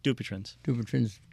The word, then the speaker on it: Dupuytren's: I got to apologize to Betty from Kingston, who cut her tendon in her index finger, and Debbie from Woodstock, who was Dupuytren's syndrome. Did I mispronounce that? – Dupuytren's syndrome.